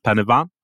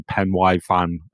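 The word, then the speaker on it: Pen y Fan: So I met up with them and climbed Penavan. I think that I'm saying that right, because I did used to say Penwai fan, and I got told off a lot for it.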